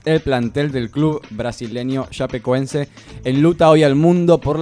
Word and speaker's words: Chapecoense: Chapacé. – Chapecoense. – Chapecoense.